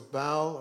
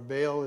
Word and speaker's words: Baal: And then there was Baal, or Baal as some pronounce it, of Canaan.